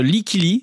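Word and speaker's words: Lykke Li: Oui, en effet, Like Lee,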